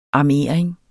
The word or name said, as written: armering